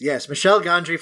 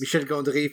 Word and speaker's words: Michel Gondry: Michel Gondry for the Anglos in the house. Yes. Michel Gondry for those who speak French. Like me. Those who know how to actually pronounce it properly.